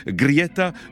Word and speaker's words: Greta: Alors si on le prononce à la suédoise, parce que je suis très curieux des prononciations locales, on dirait quelque chose comme Grieta euh, Thunberg. Voilà, quelque chose à peu près comme ça.